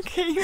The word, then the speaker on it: Keener: It's Catherine Keener. – No, it's not. – This is the funniest thing. – It's Catherine Kinnear. It is. – Okay, tell me how it's spelled. K-E-E. Sorry, just listen to the vowels. K-E-E-N-E.